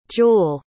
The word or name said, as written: jewell